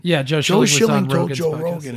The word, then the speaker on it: Schilling: I'm saying his name right? – Yeah, Joe Schilling, right? – Absolutely, yeah.